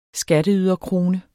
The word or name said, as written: skatteyderkrone